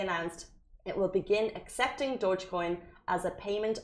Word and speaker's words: Dogecoin: A Dubai real estate co- excuse me, company will accept Dogecoin as payment. And I hope I'm pronouncing that wrong. I started saying Dogcoin. It's not how you pronounce it.